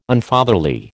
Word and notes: Unfatherly: American English

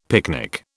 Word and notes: picnic: British English

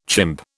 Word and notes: chimp: British English

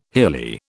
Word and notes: hilly: British English

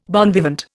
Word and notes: bon vivant: Indian

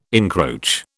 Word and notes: encroach: British English

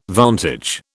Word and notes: vantage: British English

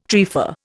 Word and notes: trefa: Indian

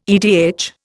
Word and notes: edh: Indian

